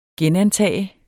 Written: genantage

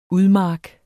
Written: udmark

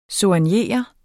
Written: soignere

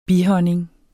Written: bihonning

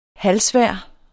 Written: halvsvær

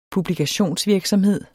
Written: publikationsvirksomhed

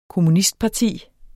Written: kommunistparti

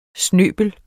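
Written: snøbel

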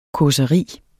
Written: CAUSERIER

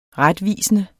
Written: retvisende

